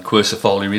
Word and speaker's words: quercifolia: Now they're, of course, closely followed on my list by the quercifolia.